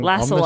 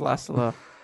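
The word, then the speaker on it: Lassila: And I said it wrong Lassala. On the show. – Lassala. I think it's Lasala.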